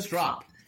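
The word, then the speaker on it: Stroup: So for the listeners who may not know the story, can we talk about – can you start with Keith – is it Strope or Stroop? – The founder of NRML. Strop. It rhymes with Strop. That's how he there we go. – Strop rhymes with cop.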